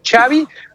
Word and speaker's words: Xavi: ¿cómo se pronuncia tu nombre? Y él fue muy claro, dijo Xavi, como si estuvieran diciendo chocolate.